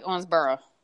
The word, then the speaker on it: Owensboro: Actually, if you say it correctly, it's more like Owensboro.